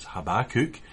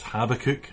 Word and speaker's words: Habakkuk: Now, I know in the overview video that it had it pronounced as Habakkuk, but I've always known this book as Habakkuk, and so that's how I'll refer to it this morning, and maybe that's how you know it too.